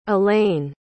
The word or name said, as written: alayne